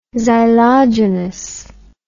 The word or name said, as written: xylogenous